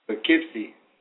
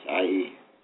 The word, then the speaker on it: Poughkeepsie: Poughkeepsie. – Okay. I was going to try, but I thought, oh, I'm going to sound like an idiot. – It's Poughkeepsie, Poughkeepsie.